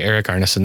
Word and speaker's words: Erik Arneson: I hope I pronounced your name correctly. – Hello, Matt. Yes, you did pronounce my name correctly, Erik Arneson.